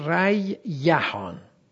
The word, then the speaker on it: ریحان: ریوحان واو به یا تبدیل شده، شده ریحان. دو تا یا در هم ادغام شده، شده ریحان. برای سهولت در تلفظ تشدید افتاده، ریحان شده ریحان.